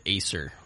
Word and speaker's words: Acer: the dentist was David Acer, like the computer Acer. – Oh, nice. Spelled the same way? So, A-C-E-R?